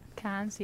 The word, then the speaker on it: Cannes: How do you Cannes, France? Is that how you say it? – Cannes, yeah.